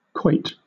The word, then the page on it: quoit: British English